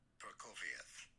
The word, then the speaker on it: Prokofiev: Prokofiev. – Prokofiev. – Prokofiev. – So I think I was close. Prokofiev. So that's how you say it.